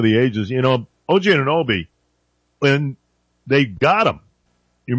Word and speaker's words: Anunoby: Anunoby, Nembe, OG Anunoby, folks.